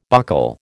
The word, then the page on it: buccal: American English